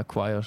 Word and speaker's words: choir: Ja, Choir spricht man so aus. Das ja, ist genau. Das eine englische Wort, wo niemand weiß, wie man es ausspricht. – Ja, Choir ist richtig.